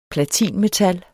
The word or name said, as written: platinmetal